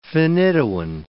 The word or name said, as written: phenytoin